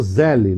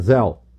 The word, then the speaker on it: Zelle: Na entrevista que o Eduardo deu à TMC, ele chegou a falar de um sistema de pagamento americano, que ele até chamou Zelle, não é Zelle, é Zelle. Se escreve Z-E-L-L-E, mas é Zelle que se fala.